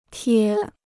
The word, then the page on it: 贴: tiē